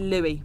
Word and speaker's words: Louis: And it's Louis. – It's spelt L-O-U-I-S. But it is pronounced Louis.